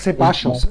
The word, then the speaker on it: Ceballos: Nein, ich sag Ceballos, Ceballos sage ich. Doppel-L wird ja ein Sch ausgesprochen.